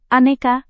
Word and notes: aneka: indonesian

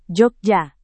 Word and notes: jogja: Indonesian

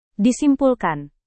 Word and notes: disimpulkan: Indonesian

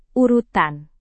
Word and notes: urutan: Indonesian

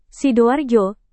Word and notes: sidoarjo: Indonesian

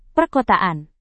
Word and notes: perkotaan: Indonesian

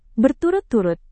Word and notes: berturut-turut: Indonesian

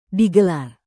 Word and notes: digelar: indonesian

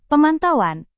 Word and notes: pemantauan: Indonesian

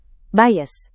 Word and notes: bias: Indonesian

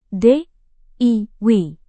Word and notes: diy: Indonesian

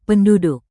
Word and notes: penduduk: Indonesian

